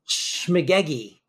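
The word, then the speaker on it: shmegegge: But the word is I never even heard this before, so I think I'm going to say it wrong. Shmugagay. Shmugagay? – Shmugagi. – Shmugagi. – Shmugagi. – Shmugagi. Which is Yiddish for baloney, nonsense, when you're full of hot air.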